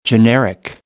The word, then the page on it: generic: American English